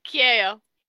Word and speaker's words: Kyaio: Kyaio.